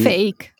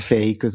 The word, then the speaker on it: PHEIC: E quindi, fake. – Esatto, se lo si legge così come è scritto, almeno all'italiana diventa fake, quindi fake news e altre cose Forse tra tutti gli acronomi che potevano scegliere se ne potevano trovare di meno infelici.